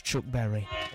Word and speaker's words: Chuck Berry: And as well as that, we'll have music from Chuck Berald. That's Chuck Berry.